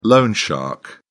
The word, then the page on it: loan shark: British English